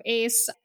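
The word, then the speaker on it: A.C.E: Yes, I'm pronouncing it Ace now, even though there's periods, I guess. Whew, they're always throwing us curveballs with K-pop. Sometimes the way it's written is not the way you pronounce things.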